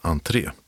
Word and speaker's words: ANH3: Den heter ANH3, vilket ska uttalas entré, och ligger på Roskildegatan 2.